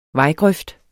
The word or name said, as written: vejgrøft